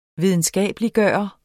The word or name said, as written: videnskabeliggøre